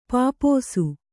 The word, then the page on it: ಪಿಪಾಸೆ: kannada